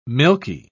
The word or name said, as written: milky